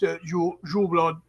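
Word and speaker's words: Yuleblót: The Yuleblatt is not, and it's actually pronounced Yuleblod. And people think it means blood. It doesn't. The word blot with the T is pronounced like blood.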